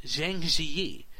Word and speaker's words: Zhangjiajie: Well, a few days ago, the Chinese city of Zhangjiajie, located, did a pretty good job pronouncing it.